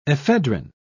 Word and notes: ephedrin: British English

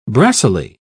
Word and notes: brassily: British English

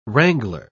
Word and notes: wrangler: British English

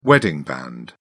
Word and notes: wedding band: British English

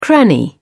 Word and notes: cranny: British English

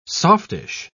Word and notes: softish: British English

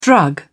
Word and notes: drug: British English

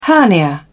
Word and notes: hernia: British English

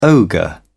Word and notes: ogre: British English

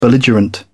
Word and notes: belligerent: British English

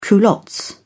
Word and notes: culottes: British English

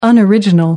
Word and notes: unoriginal: American English

